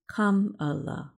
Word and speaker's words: Kamala: During Harris's campaign for president this year, 2 of her young relatives spoke at the Democratic National Convention to teach the crowd how to say her first name, pronounced Kamala.